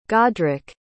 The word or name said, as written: godric